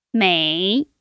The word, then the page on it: mei: 3